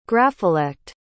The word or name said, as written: grapholect